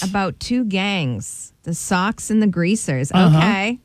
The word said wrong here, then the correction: Socs: It's the Socs and the Greasers. – It's spelled S-O-C. – They call it the Socs, though.